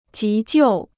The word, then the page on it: 急救: jí jiù